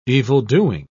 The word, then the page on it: evildoing: British English